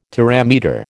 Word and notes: terametre: American English

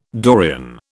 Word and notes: Dorian: British English

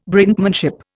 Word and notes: Brinkmanship: Indian

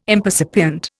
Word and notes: impercipient: Indian

